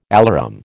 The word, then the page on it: alarum: American English